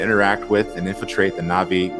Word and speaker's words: Na'vi: It's Na'vi because there is an accent between the syllables, so Na'vi.